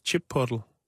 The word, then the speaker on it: Chipotle: så det hedder udtales Chipotle.